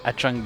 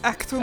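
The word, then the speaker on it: Achtung: On prononce Actum. – Actum, Actum Baby. – Euh, le, baby qui éternue, Chum Baby. – Ah! Non, Actum, c'est en allemand.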